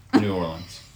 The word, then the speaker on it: New Orleans: Okay, so I gotta ask, what's the appropriate way to say it? Is it New Orleans, Nolens, Nola? How do we say this? – New Orleans. – We know. What's the difference? – Well, NOLA is just the abbreviation of New Orleans, Louisiana. – Yeah. – Nolens is just slang.